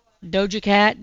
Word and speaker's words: Doja Cat: Dova Cat, is that how you say it? Doja Cat, Dova Cat, Doja Cat, Paint the Town Red.